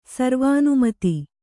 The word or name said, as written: ಸರ್ವಾನುಮತ